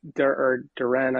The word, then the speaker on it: Duren: Duren, I believe, is the correct pronunciation, on draft night.